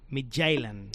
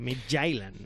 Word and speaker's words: Midtjylland: sino que tiene también al Midtjylland. – Efectivamente, con propio de Copenhague ese acento, ¿eh? Esa pronunciación. – Sí. Midtjylland.